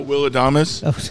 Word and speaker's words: Adames: It's your guy, and you're talking Trey Turner, Carlos Correa again, Bogart, Seager, Lindor, Swanson, Bo Bichette, Will Ames. How do I say his name? Is it Will Adam? The guy that's on Milwaukee? – Oh, Will Adames.